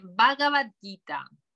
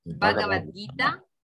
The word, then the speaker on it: Bhagavad Gita: Y es, en español se dice Bhagavad Gita. Bhagavad Gita. Se escribe con la H, es Bhagavad, o sea, cuando la gente empieza a buscar, bueno, que pongan Bhagavad Gita y les saldrá.